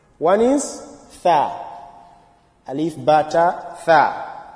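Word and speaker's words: tha (ثاء): Tha, the tongue will come out. If you pronounce this letter and the tongue is not out, you must know it's wrong. And it's not sa. Some they will, instead of tha, they will say sa.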